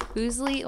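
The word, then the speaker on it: Fuslie: So now I'm starting to think it's pronounced Foosley, yeah, whatever, but it's Foosley.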